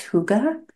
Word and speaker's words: hygge: I think one of the most well-known ones, it's pronounced hygge.